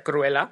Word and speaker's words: Cruella: A ver, que se pronuncia Cruella de todas formas, pero lo que tiene el inglés, ¿no? Dos L's, una L.